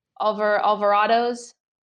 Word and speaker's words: Alvarado's: So if people are confused because I butcher the name, it's like Alvogadro's Bakery. Have you heard of it? Alvarado's? – I don't know.